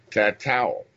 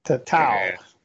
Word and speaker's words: Tatu: We're going to chill out today with Tattoo Barbecue, or as the name is supposed to be pronounced, and I hope I didn't forget how to do it on my page, Tatowl. – Tatowl. – Tatowl. And but it is spelled T-A-T-U.